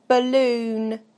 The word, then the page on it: balloon: British English